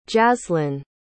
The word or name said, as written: jaslyn